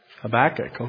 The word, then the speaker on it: Habakkuk: Do you say Habakkuk or Habakkuk? Habakkuk, okay. It just depends what syllable you put the emphasis on, I guess.